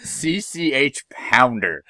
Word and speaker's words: CCH Pounder: I just pronounce it CCH Pounder.